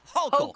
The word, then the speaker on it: Hochul: It's Hochul. – Hochul is way cooler than Hochul, by the way. – Yeah. It rhymes with local. So when I first ran for office, think local, vote Hochul, okay? – That's all I remember.